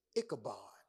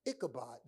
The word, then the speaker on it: Ichabod: Ichabod. Ichabod. Now, if you take, it's three syllables, but if you take the first the prefix away, the ick away, which means heavy, The name Kabod simply means glory.